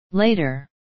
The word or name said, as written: later